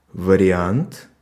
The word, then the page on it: вариант: /və.rʲɪ.ˈant/